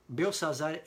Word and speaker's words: Belsazar: É, Belsazar é a pronúncia em hebraico, mas a pronúncia babilônica era Bel-Sar-Uzur, que significa o deus Bel protegeu o rei.